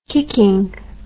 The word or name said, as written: kicking